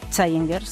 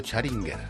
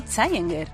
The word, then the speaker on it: Challenger: Challenger. Si no se dice así, lo siento. Challenger. – Ha dicho Charinger. – Que no, Challenger.